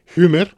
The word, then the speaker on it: Huemer: Huemer es un apellido germánico, por tanto, UE se pronuncia en alemán Huemer.